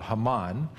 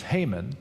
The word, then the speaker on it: Haman: Time went on, and this really bad guy named, in Hebrew it would be called Haman, but in English you hear his name pronounced Haman.